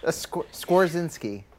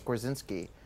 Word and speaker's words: Skorzynski: and then she said a pronunciation of my name, and I was like, no, it's Skorzynski, and she's like, I would know how to pronounce your name because I'm from Poland.